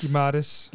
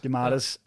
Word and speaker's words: Guimarães: øh, kan I huske, hvordan man udtaler det? Guimarães? – Guimarães. – Guimarães, ja.